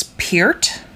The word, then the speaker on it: Peart: You're telling me it's Peart? – I think that's actually how you say it.